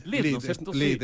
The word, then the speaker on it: LEED: Y también se ganaron la certificación, ¿se pronuncia LEED o LEED? – LEED, es norteamericano, sí.